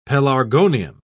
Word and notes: pelargonium: British English

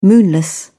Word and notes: moonless: British English

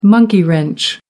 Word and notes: monkey wrench: American English

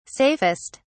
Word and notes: safest: British English

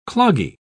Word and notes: cloggy: British English

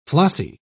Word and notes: flossy: British English

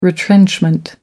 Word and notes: retrenchment: American English

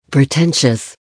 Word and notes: pretentious: American English

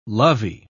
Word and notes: lovey: British English

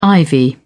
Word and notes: ivy: British English

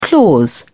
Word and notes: clause: British English

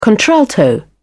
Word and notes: contralto: British English

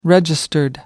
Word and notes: registered: American English

